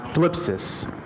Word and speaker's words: thlipsis: This word, tribulation, in the Greek, it's pronounced thlipsis.